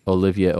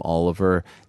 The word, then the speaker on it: Olivier: He mispronounced Olivier Aubert-Mercier's name. He called him Oliver, and then he also mispronounced Brendan Loughnane's name.